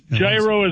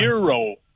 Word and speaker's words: gyro: Gyro is not the proper pronunciation. – That's fine. – Euro, like why, like the year.